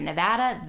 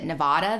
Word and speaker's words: Nevada: When I moved across the country to Nevada, that was hard. And I pronounced it Nevada then. And they teach you when you move there, it's Nevada.